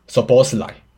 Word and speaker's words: Szoboszlai: 然后他就很震惊，就说：“ 嗯，Dominic Szoboszlai。”